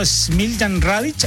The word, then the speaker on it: Smiljan Radic: El destacado arquitecto chileno Smiljan Radic,